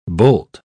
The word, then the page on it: boult: British English